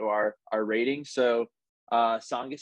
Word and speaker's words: Tsongas: Yeah, so uh, we're gonna say Tsongas. It's spelt very weirdly. Uh, if, if you just look in the in the title of this episode, I've been told the T is silent.